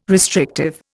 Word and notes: restrictive: Indian

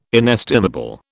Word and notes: inestimable: British English

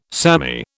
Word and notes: samey: British English

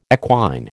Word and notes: equine: American English

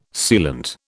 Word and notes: sealant: British English

